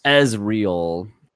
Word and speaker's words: Ezreal: Ezreal, he wants us to know it's pronounced not like Israel. He wants us to be well aware of that. – Ezreal.